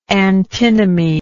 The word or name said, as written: antinomically